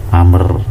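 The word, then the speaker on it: amr (عمرو): Contohnya yang kemasukan lamul ibtidak la zaitun afdol benar zaid itu afdol lebih baik min amrin dari amr ya. Jadi ini bacanya amr ya kalau ada ain mim rok wawu itu Amr kalau nggak ada wawunya bacanya Umar malah la zaitun lamun lam alamu lamul ibtida zaitun mubtadaun marfuun bidomah ya.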